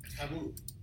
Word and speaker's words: Camus: Camus. – And yes, we're going to talk about Albert Camus, spelled Camus. And I don't know why, if it's a silent U, you put an S, but that's what French, of course, French, you don't ask any questions on the French because they know everything.